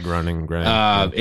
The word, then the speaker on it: Groening: Is Matt Groening, how do you say the last name? Groening? – Yeah, Groening.